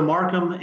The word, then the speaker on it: Markham: Yeah, so they can find me on LinkedIn, Blake Markham. – And spell that, the last name, so they can get that correct, please. – Yeah, so Markham is M-A-R-K-H-A-M.